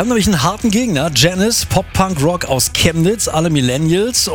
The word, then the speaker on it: Janiz: Das Einfachste, glaube ich, tatsächlich googeln und im Kopf behalten, dass wir Janis ausgesprochen werden. Und dann findet ihr alles, was es über uns zu wissen gibt. – Ja, nur Aussprache bei Google immer schwierig. Schreibweise ist wichtiger. J-A-N-I-Z.